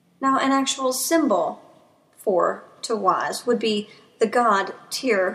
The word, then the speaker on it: Tiwaz: Now, the first rune we're going to be looking at from Tirs Eyet is Tawaz. It is spelled T-I-W-A-Z. That's Tawaz. Now, literally, this rune looks like an arrow pointing in an upwards direction.